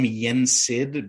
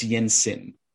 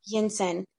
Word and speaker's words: Yinsen: Kind of, I want to call him Yensid because that's the the sorcerer in Fantasia but it's Yinsen. – Yinsen.